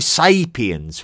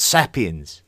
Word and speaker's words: sapiens: It is Homo sapiens with a long A, not sapiens. It is Neanderthals with a th and not Neanderthals.